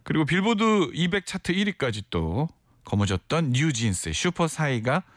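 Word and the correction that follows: Super Shy: 죄송해요. 발음이 너무 저질이었죠. 네. 아, 부끄럽네요. 베리 슈퍼샤이하네요. 예. 슈퍼샤이 슈퍼샤이가 사이가 아니라 슈퍼사이 슈퍼샤이가 많은 분들의 사랑을 받았습니다.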